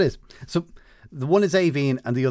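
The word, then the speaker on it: Aoibhinn: which is A-O-I-B-H as well, but it ends with E-A-N-N. And the first one of those is pronounced Avine, so you were close.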